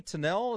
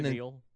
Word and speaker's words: Tennille: So anyways, Tony Tennell is turning 78. The captain. – Tennille.